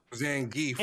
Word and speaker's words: Zangief: Zangief. – It's Zangief. That's how you pronounce it.